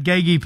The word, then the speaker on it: Geki: Tell us the story about Geki, please. Is that the right pronunciation? – Oh, too deadly, brother. You got it. You nailed it all in one.